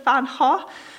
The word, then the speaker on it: van Gogh: and he said that the V should be pronounced as an F, so it's Vincent and Van, and then the go would be with a silent G, so it's Ho. So I think it's Vincent van Ho,